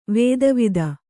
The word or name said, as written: ವೇದವೇತ್ತ